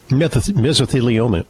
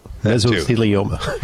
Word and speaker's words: mesothelioma: How do you pronounce that? – Mesothelioma. Mesothelioma. – Right.